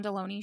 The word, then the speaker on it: Delony: maybe I didn't really know as far in detail as it was going to go, you know, but it was the John Delaney show. Like, I always have to say this just because I think it's funny, but like baloney, but without the B, and that's actually not even how it's spelled.